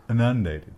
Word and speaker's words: inundated: I think you put the emphasis on the wrong syllable. – Did I? Oops. How do you say it? How do you say it? – Inundated. – Inundated. Inundated.